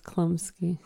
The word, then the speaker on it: Chlumsky: Chlump, there's a CH. – Yeah, it's pronounced Chlumsky.